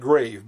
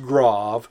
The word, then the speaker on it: grave: The acute accent, the grave accent that looks like grave, but it's pronounced grave, and the circumflex accent.